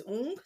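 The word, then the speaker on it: Woong: So in season one, her main love interest is it's spelled Wong, but she pronounces it as Ung, so I'm gonna call him Ung.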